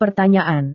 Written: pertanyaan

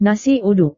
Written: nasi uduk

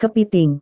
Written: kepiting

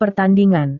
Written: pertandingan

